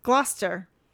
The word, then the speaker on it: Gloucester: And what I said was Gloucester is pronounced Gloucester because English makes no sense.